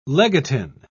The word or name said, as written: legatine